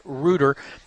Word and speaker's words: Reuter: That is, in fact, Reuter, as in root for the home team. Tripp Reuter is the correct pronunciation